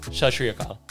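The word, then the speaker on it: Sat Sri Akal: So it's basically Sashriyaka. So SAT, that's the first word. SRI, the yeah. second word, and then Akal, AKAL, Shashri Akal.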